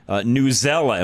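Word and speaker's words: Newsela: Newzella. Am I saying that correctly? – That's exactly right.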